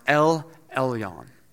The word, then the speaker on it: El Elyon: In Hebrew, it's pronounced El Elyon.